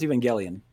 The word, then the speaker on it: Evangelion: So it's Evangelion, according to Google. – Evangelion. – Not gel. Yep. Like I was. It's Evangelion. Yep, correct.